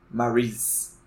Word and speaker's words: Marise: ele especificamente nos ensina a pronunciar o nome da Marise corretamente, que é como o Dante falou, Maurice. Só que eu gosto de Marise.